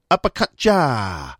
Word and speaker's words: Apakatja: The band are called Apakaja. I think that's how you pronounce it. It's also difficult. Oh, I've just found a website. It's pronounced Apakaja.